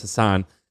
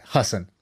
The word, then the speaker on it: Hasan: But, you know, I know it's Hassan. – By the way, I think he pronounces it Hassan.